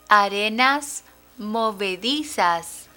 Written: arenas movedizas

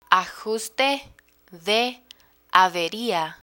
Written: ajuste de averia

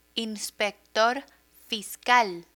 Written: inspector fiscal

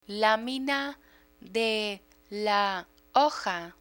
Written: lamina de la hoja

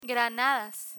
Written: granadas

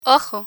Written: ojo

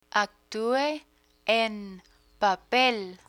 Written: actue en papel

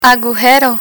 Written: agujero

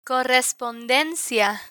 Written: correspondencia

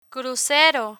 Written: crucero

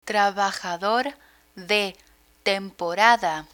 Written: trabajador de temporada

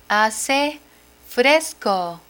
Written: hace fresco

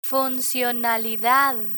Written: funcionalidad

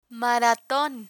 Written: maraton

